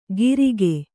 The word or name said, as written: ಗಿರಿಕೆ